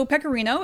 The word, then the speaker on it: Pecorino: So Pecorino, it's just like the cheese. It sounds the same.